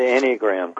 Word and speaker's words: Enneagram: Now, my question is, did I pronounce that correctly, Enneagram? – Yes, the Enneagram, correct.